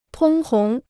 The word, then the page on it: 通红: tōng hóng